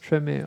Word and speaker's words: Tremé: In der Serie wird es Tremé ausgesprochen. Ah, okay, dann passt es.